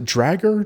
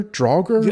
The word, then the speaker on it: Draugr: Also, there's going to be these other monsters called, how did you pronounce it? Dragger? Draugr? – Yeah, that sounds good. Yeah, that's good. – D-R-A-U-G-R.